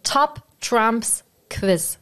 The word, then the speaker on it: Top Trumps Quiz: Also, Top Trumps Quiz. So heißt das. Jetzt nochmal richtig ausgesprochen.